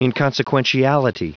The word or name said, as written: inconsequentiality